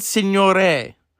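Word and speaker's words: Signoret: So Ship of Fools has Vivien Leigh, Jose Ferreira, Lee Marvin, Simone Segre. Is that how you say her name? I always said her name wrong.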